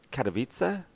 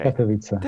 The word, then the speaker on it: Katowice: Okay, so let's talk about Katowice, which I've had a bit of trouble pronouncing, but I think I'm saying that right. Katowice? – Perfect. Okay. – Katowice.